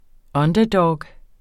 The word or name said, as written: underdog